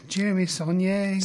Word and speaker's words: Saulnier: Is that, I'm saying that right? Saulnier? – No, Jeremy Saulnier. – Saulnier? That works. Sure.